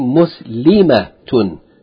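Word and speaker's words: Muslimat (مسلمات): Whereas in the plural form, the A is a long A. Muslimat, muhandisat.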